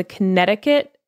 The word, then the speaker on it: Connecticut: So the Gifting Tables pyramid scheme, which I'm going to focus on today, took place along the Connecticut shoreline in New Haven County. And one thing that I learned from this episode is that Connecticut has a silent C.